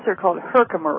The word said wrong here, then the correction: Herxheimer: reactions. I'm mispronouncing it. After the person who discovered them. – Yeah, Herxheimer.